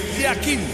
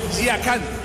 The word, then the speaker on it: Yakin: En el suizo se pronuncia así: Murat Yakin. En turco, Yakan. Cambia la última parte de su apellido.